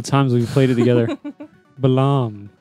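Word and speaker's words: Balamb: Is that how you spell it? – I say Balam. – I say Balam, too. – I say Balam. – Or really? – Balam. Mm-hmm. – Like a quiet B.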